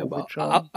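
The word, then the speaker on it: Ouwejan: Dann könnte natürlich Johannes Geis vielleicht auch noch ein bisschen wacher sein und äh, den äh, Uwe Jan, ne? um das, das richtig ausspreche, ja, Ovejan. Ovejan. Ovejan.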